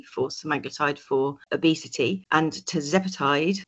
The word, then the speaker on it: tirzepatide: So Ozempic, Wegovy, and then the new one, Mounjaro, which is, if I can pronounce this right, tirzepatide. – Tirzepatide, exactly. And tirzepatide is a GLP plus, so to speak.